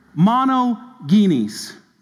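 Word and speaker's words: monogenes (μονογενής): The Greek word used here for this is a word pronounced this way, monogenes. Monogenes, or unique.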